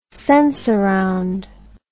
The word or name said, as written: sensurround